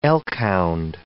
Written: elkhound

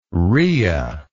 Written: ria

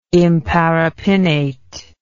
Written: imparipinnate

